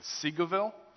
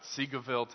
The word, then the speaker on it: Seagoville: We have Pastor Chris Knowles coming from Victory Baptist Church in Seagoville. Yes. Saying it right. I've said it wrong like 15 times from the stage before, but Seagoville, Texas.